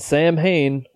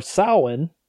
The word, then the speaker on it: Samhain: And he's written Samhain, or Samhain, as you would actually say it, on a chalkboard in blood.